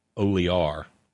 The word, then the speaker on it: Olear: So, and I'm told he pronounces it Olear.